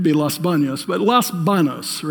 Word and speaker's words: Los Baños: Is it Los Banos or Los Baños, the city? Banos. Yeah, okay.